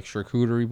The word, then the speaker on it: charcuterie: Yeah, and that's like they're big into like charcuterie boards. I don't know if I'm even saying that right. – Yeah, no, you're good. – But like you know, the charcuterie is the big thing now.